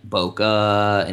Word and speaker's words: bokeh: And people call it like bokeh and like bokeh and they don't know. No one knows how to say it. So I looked it up, and it is bokeh.